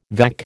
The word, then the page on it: vac: American English